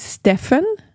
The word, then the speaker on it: Steppen: Il y a des sources qui disent Steppen, mais son nom s'écrit, j'aurais pas pu le deviner. – S-T-E-P-P-E-N. – S-T-E-P-P-U-H-N. – OK.